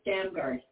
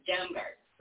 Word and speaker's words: Damgaard: Oh, it's Damgaard. – Damgaard. – And I always say, like, you're swearing. That's what I tell people. Like you're swearing. Damgaard.